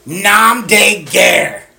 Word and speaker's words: nom de guerre: Nom de guerre. I probably said that. – What's it mean? – No, you said it right.